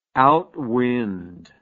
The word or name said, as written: outwind